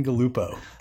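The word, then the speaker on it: Galuppo: Dan Galupo.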